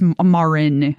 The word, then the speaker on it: Marin: Is it Marn? – It is. It is. It is High Priestess Marin. – Marin is the way it's spelled.